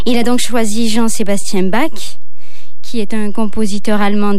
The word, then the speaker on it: Bach: Je vais vous parler de la rencontre étonnante entre la musique du compositeur allemand Jean-Sébastien Bach, pour la prononciation française, et un instrument qui a été inventé presque 2 siècles après sa mort, l'accordéon.